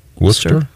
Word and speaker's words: Worcester: Okay, well, let's take a Before you go, the how do you pronounce Worcester? Is it Worcester, Massachusetts? – Worcester.